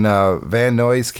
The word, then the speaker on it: Van Nuys: In Van Nuys, California. – Yeah, Van Nuys.